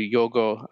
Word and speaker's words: Yorgos: Now, Poor Things is the new Yorgo Lanthimos film. I got corrected by Emma Stone. I saw a video of her online where she called him Yorgo, not Yorgos, because the S is silent.